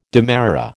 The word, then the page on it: demerara: American English